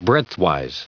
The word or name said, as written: breadthwise